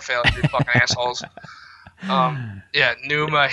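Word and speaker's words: Pneuma: Yeah, for anyone out there listening, it's spelled with a P, right? – Yeah. – P-N, yeah. – Like Numa. – Like pneumonia. – Yeah. Yeah. Yeah, there you go.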